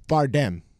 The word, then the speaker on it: Bardem: Bardem? – Bardem.